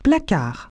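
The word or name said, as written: cupboard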